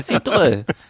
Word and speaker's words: Cidre: Ja, wie heißt denn, wie wird denn eigentlich Cider auf Französisch ausgesprochen? Cidre? – Wahrscheinlich so weit, ja. Ich würde mich jetzt nicht so weit aus dem Fenster nehmen. – Cidre. – Cidre. Oh, Cidre. – Ja, genau.